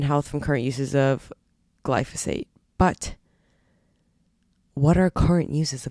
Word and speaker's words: glyphosate: They found ridiculous amounts of, what is it, glyphosate? Let's go back, hold on. Glyphosate, that's how you pronounce it.